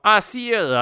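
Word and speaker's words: Æsir: They're like thunder god of the Asgard, of the, how do you say Asar in English? – Yeah, Asir, I think, is the pronunciation.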